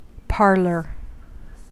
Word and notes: parlor: American English

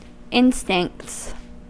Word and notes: instincts: American English

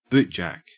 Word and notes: bootjack: Australian English

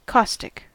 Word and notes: caustic: American English

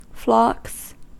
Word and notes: flocks: American English

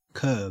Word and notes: kerb: Australian English

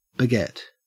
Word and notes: beget: Australian English

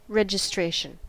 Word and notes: registration: American English

